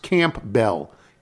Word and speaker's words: Campbell: The town I live in is Campbell, commonly mispronounced like the soup. Here it is pronounced Campbell. Campbell, two words.